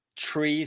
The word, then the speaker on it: tree: Observen que esa tree suena como CH, tree.